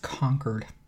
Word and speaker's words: Concord: I believe it's pronounced Concord. It's New Hampshire.